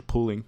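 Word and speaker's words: Pulling: So in Richmond, Virginia, this lady called Patricia Pooling, am I saying that right, Kate? – Yeah.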